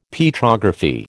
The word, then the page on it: Petrography: American English